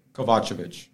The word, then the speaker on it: Kovacevic: Um, if you say my name the right way, it's Kovacevic, right? – And that would be Kova.